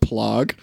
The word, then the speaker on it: plague: We got bubonic plaque. It wasn't the same thing. – It's pronounced plague.